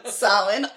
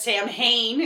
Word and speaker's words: Samhain: And Samhain? – Samhain, only the most famous. – Yes, also known as Samhain, if you're reading it. – No, it's not. But it looks like Samhain.